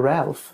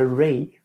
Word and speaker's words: Ralph: Inte heter, varför kallar han sig inte bara Ralph? – Ja, eller hur? Som alla andra vet jag. – Han stavar ju sitt namn Ralph Fiennes. – Fiennes. – Men han envisas med uttalade Ralph.